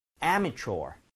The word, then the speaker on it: amateur: 요거 강세 앞에 줘야 됩니다. 이 부분을 제일 많이들 틀리세요. 자, 앞에 두고 두 번 한번 따라해 보도록 하세요. 이런 느낌. Amateur.